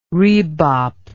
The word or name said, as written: rebop